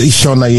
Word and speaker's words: Shona: Shona, yes.